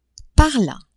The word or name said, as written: par la